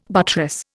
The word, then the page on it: buttress: Indian